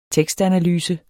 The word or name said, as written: tekstanalyse